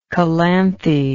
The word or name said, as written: calanthe